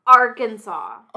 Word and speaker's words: Arkansas: So it's like a little bit tricky how it's read because like no one ever pronounces the S. So I actually always spell it wrong because it's Arkansas.